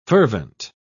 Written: fervent

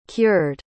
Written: cured

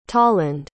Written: tolland

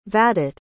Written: vadit

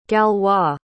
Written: galois

